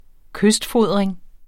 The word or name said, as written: kystfodring